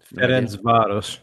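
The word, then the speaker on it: Ferencváros: Is that how you say it? FERENCVAROS? No? – FERENCVAROS, yeah.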